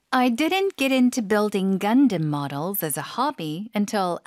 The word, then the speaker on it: Gundam: Gundam. – Gundam. – Gundam. – 앞쪽에 힘 주고 뒤쪽에 살짝 약화시켜서. 네. 영어식 발음입니다.